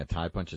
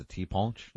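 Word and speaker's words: ti' punch: So was I mispronouncing what I was saying? A tie punch? It's a tiponche? – Yeah.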